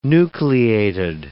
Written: nucleated

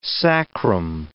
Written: sacrum

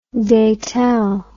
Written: datel

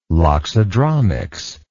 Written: loxodromics

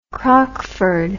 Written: crockford

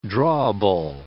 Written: drawable

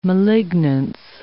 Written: malignance

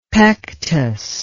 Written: pectous

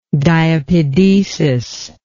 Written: diapedetic

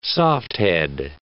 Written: softhead

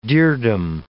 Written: dirdum

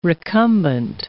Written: recumbent

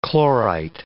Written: chlorite

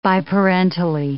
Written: biparentally

